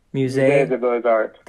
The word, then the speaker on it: Musée des Beaux-Arts: I can't pronounce it. You say the Museum of Fine Arts title. – Musée des Beaux-Arts. – Des Beaux-Arts. Okay.